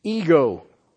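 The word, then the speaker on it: ego (ἐγώ): Epsilon, gamma, omega. It's always translated as a personal pronoun in the Greek. You know how it's pronounced? ego. Ego.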